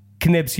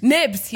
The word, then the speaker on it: Knibs: Knibs Hughes. – Nibs Hughes. Oh. Silent Knibs. K, like a doorknob. – Sorry, it's a silent K.